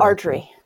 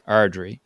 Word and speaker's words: Ardrey: Ardrey. Ardrey.